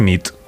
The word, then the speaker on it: nit: Y noche es nit. – Muy bien. Buena nit. – Buena nit, es verdad. – Buena nit.